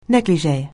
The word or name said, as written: negligee